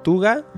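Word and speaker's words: Cthugha: Se llama Hayore Nyaruko-san, donde las protagonistas son Nyarlathotep, Tuga, que se escribe como Tulu, pero con G-H-A, Tuga, y Hastur, que según cuentan son deidades del universo Lovecraft.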